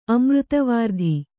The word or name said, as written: ಅಮೃತವಾರ್ಧಿ